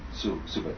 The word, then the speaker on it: tsuba: Suba, T-S-U-B-A, the T is silent.